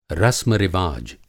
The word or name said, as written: रस्म-रिवाज